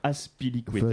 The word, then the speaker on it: Azpilicueta: Azpilicueta. – Voilà, César Azpilicueta.